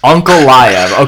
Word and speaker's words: Ankalaev: This is the easiest way to learn how to say his last name. Okay. Uncle Lyov. – Okay, Magomed Uncle Lyov. – But then you take the U and put an A.